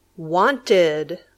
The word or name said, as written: wanted